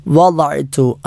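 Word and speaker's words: വദഅ്തു (وضعت): വദഅ്തു, വദ എന്നത് ദ നാവ്, ഇടതുഭാഗം ഇടതു പല്ലിലോ അല്ലെങ്കിൽ വലതുഭാഗം വലതു പല്ലുകളിലോ സ്പർശിച്ചു കൊണ്ട് ഉച്ചരിക്കേണ്ട അക്ഷരമാണ് ദ എന്നത്. ലാ എന്ന് നാവ് മുൻപജിലേക്ക് ചേർത്തുകൊണ്ട് ഉച്ചരിച്ചാൽ തെറ്റാണ്.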